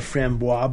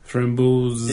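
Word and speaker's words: Framboise: Lindemann's Rambos, or you may hear it be called Frambois, but I believe that the correct pronunciation... – Frambos. – Is Frambos or something along those lines. But if you call it Frambois, no one's going to give a crap, so...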